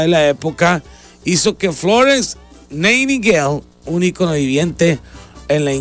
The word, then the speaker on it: Nightingale: También hoy nace Florence Nightingale, así se pronuncia.